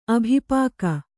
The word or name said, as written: ಅಭಿಪಾಕ